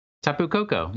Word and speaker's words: Tapu Koko: Am I saying that right though? Tapu Koko. Tapu Koko. Yeah.